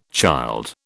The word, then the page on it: child: British English